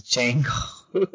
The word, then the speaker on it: Django: Yeah, he told me the D is silent, so I have to stop saying that. It's Django. it's Django slash Zorro.